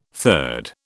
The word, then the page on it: third: British English